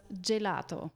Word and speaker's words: Gelato: Also folgt auf dem Buchstaben G ein E oder ein I, so wird das G dann wie ein J ausgesprochen, also ein bisschen weicher. Ja, das beste und hervorragendste Beispiel ist dann natürlich das Gelato.